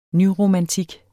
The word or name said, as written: nyromantik